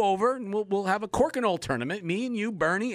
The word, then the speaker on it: Crokinole: I didn't quite I'm going to, yeah, Crokinole.